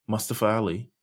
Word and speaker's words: Mustafa Ali: We're at least getting it now because Mustafa Ali, or is it Mustafa? Am I saying it right? – Mustafa Ali. Yes.